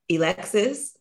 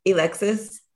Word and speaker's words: Alexis: And what he said was, Alexis, which is the way he pronounced my name, Alexis, I realize I work too damn hard to work for somebody else.